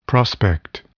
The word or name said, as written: prospect